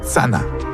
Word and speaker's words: channa: Par exemple, pour dire arrêt en Abenaki, on dit tsana. En Abenaki, ça s'écrit C-H-A-N-N-A, mais il n'y a pas le son C-H. À la place, on utilise le son T-S. Et donc, ça donne Tsana.